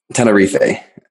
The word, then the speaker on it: Tenerife: Tenerife, technically. – Okay. Americans have never heard of this place before, so none of us really know how to say it. – Most English speakers like call it Tenerife,